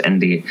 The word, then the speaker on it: Ende: The author, Michael Ende, I think it's pronounced Ende, he was actually a Thelemite and an occultist.